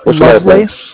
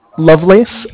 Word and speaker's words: Lovelace: It's R-Y-A-N. – Oh, Y. Okay. Okay, last name? What's your last name? – It's Lovelace. Lovelace. L-O-V-E-L-A-C-E. – L-O what? – L-O-V-E, like love, yeah? And then L-A-C-E, yeah, Lovelace.